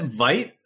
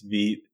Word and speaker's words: Vite: I still get people going like, is it Vite? I'm like, no, it's Vite. And they're like, why is it pronounced Vite? I was like, it's French.